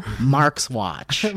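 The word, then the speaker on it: Marxuach: How we finally found out how to say it correctly, and we all say it like this. Javier Grillo Marxwatch.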